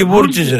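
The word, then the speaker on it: Βρούτση: Όχι Βρούτσι, ρε, Βρούτσι. – Ρε, Βρούτσι, δεν το βάκο προφέρω.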